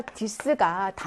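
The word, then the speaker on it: dix: 요거는 숫자 dis가 단독으로 발음하면 dis고요. 뒤에 모음이 나오게 되면 dis라고 해서 이렇게 z 발음으로 바뀌죠. dis oiseaux.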